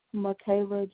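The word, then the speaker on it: Makayla: Michaela Johnson, M-A-K-A-Y-L-A. J-O-H-N-S-O-N.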